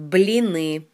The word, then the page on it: Blini: Russian